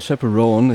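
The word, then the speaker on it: Chappell Roan: Nämlich, oh mein Gott, dieses Mal kann ich es richtig aussprechen. Pink Pony Club von Chapel Roan. Ich habe es Chapel Roan das letzte Mal ausgesprochen, wie ein Vollidiot.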